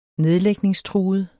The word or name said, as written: nedlægningstruet